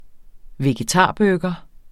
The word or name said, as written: vegetarburger